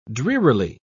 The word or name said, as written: drearily